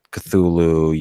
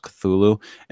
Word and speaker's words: Cthulhu: And I guess he had wrote down like how you're supposed to pronounce Cthulhu, and it's not supposed to sound like that. It's supposed to be two syllables.